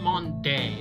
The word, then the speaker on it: Maundy: But it's not Maundy, it's Maundy. M-A-U-N-D-Y.